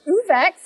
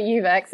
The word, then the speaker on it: Uvex: Uvex, I used to say Uvex, it's Uvex.